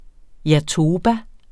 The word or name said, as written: jatoba